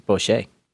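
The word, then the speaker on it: bochet: It's Boucher.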